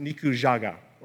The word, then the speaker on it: Nikujaga: Apparently, I've been telling others that the dish I was making and eating was called Meat Island, not meat stew, which would have been properly pronounced Nikujaga, or I've still probably butchered it, if I pronounce it properly and use the right word.